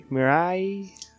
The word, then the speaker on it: Mirai: What, how do you say it? Mirai, yeah, Mirai. It means like future in Japanese.